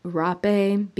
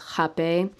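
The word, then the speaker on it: Rapé: So Jape is, a lot of people call it rape because of the way that it's spelled. But the pronunciation is jape.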